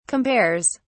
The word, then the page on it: compares: British English